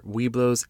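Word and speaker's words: Webelos: Side note, just to be clear, Weeblos is pronounced Weeblo, which is very misleading considering the no-gay rule they had for so long.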